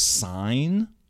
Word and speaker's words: Sin: So, by the way, Sin, S-I-N. – Mm-hmm. – So I'm pretty sure it's pronounced sign.